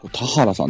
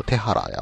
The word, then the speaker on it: 田原: 田原さんと読むんですね、これね。ずっと手原や思ってましたけど。